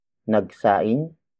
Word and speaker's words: nagsaing: Word for word, it's nagsaing ako para kay Tita Sylvia. Nagsaing ako para kay Tita Sylvia.